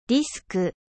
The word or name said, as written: disque